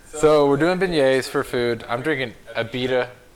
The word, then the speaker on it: beignets: So, Blake's making beignets.